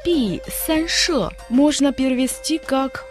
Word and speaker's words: 避: Слово «пи» тоже в четвертом тоне, означает «прятаться», «отойти в сторону».